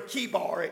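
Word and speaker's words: Chebar: He's at the river Kibar. It's spelled with a C, but it's pronounced with a K.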